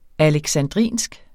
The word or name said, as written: alexandrinsk